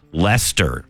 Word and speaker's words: Leicester: First of all, Emma letting me know that her dad was from Leicester.